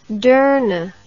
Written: deurne

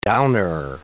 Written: downer

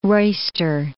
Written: roister

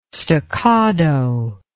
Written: stoccado

